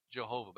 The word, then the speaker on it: Jehovah: Sometimes we pronounce it Jehovah.